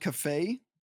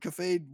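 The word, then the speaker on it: Kafei: is he turns a character called Cafe, which I think that's how you pronounce it. It's K-A-F-E-I. It's a weird word, weird name.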